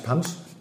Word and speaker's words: Punch: Ausgesprochen wird es ja eigentlich Punch. Und Punch kommt aus dem Indischen, heißt 5, weil 5 Zutaten drin sind im Punch. Nur mal kurz, es sind 5 Ingredienzien, die reingehören, deswegen heißt, weil Indisch wird es eigentlich ausgesprochen, Punch, 5 und deswegen Punch.